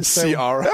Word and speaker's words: Ciara: That's how you say it. Say it again. – Sierra. Sierra.